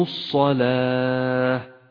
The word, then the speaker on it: الصلاة: ولاحظ أن اللام التي في أولها لام شمسية، ولذلك لونت بالرمادي إشارة إلى أنها لا تلفظ، وكذلك حرف الواو أيضا فهو من زيادات الرسم العثماني. ولكن تنبه إلى أنك إذا أردت أن تقف على هذه الكلمة فقف بها إن ساكنة وليس بتاء، فقل هكذا وأقاموا الصلاة، وأما في حالة الوصل تكون تاء هكذا وأقاموا الصلاة وأنفقوا، فتنبه.